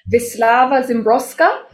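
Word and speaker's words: Wisława Szymborska: Her name is pronounced Wisława Zimbrowska, which I did not know before because I don't know how to read Polish names.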